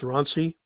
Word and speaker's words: Ronsse: My last name is spelled Ronsse, pronounced Ronsi.